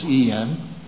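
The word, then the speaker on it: en (ἐν): We have it's a Greek preposition that's spelled en in Greek, pronounced n, but it's en.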